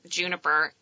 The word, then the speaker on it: Junapr: Um, and we actually have on our website Juniper and then in parentheses pronounced spelled out juniper like the yeah, berry so exactly.